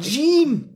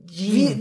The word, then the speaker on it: Jean: Jean. – Jean. Wie, du kannst doch das Wort Jeans sagen, oder? So, Sag mal Jeans.